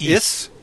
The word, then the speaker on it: Ys: It's pronounced is.